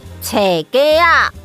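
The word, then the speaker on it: 冊架: 书架台语念作册架，册架。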